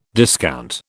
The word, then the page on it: discount: British English